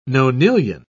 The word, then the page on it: nonillion: British English